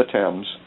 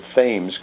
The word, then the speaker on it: Thames: Well, I started to the right along the Thames. Some pronounce it Thames because it's spelled T-H-A-M-E-S, but over there they pronounce it Thames.